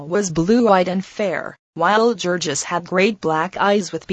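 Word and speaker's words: Jurgis: And she had just been married and married to Georgius, pronounced Georgius of all men,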